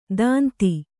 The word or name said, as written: ತಂತಿ